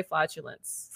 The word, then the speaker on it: flatulence: How do you say flatulence? – Flatulence. – How do I say flaudulence? – Flatulence. – Well, I just said it. Did I say it right? – Say it again.